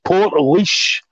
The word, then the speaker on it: Portlaoise: So anybody that's been listening, anybody from Ireland, is probably thinking, wait till you get over to Ireland because you've been pronouncing this name very, very wrong. So Portlaoise, I believe it is.